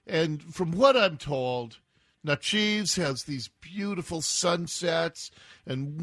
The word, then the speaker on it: Natchez: Hope I'm saying it right. You Mississippians. Natchez? Natchez? Nacho cheese? Nacho cheese, Mississippi? No, Natchez, Mississippi, which is a historic city.